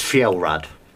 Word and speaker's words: Fjelrad: How do you pronounce your name, bro? – It's Fjellrad. – Fjellrad. – And don't worry, everyone mispronounces it.